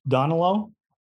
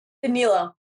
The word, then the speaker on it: Danilo: Donilo? – Danilo.